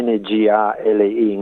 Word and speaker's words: Ngali: Allora con NG, si scrive NGALI, Ngali, e tante parole del Kamilaroi, lingue aborigene, cominciano con quel suono.